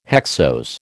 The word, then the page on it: hexose: American English